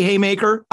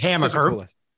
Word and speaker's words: Hamaker: I have the distinct privilege of actually interviewing the host, Pace Ellsworth, BJ Hamaker. Oh man, I said it wrong again. Hamaker is such a cool last name. It's Hamaker.